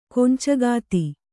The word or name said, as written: ಕೊಂಚಗಾತಿ